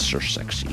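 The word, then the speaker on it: Ser Sexy: And everybody likes to say that, Sir Sexy. – It's well pronounced.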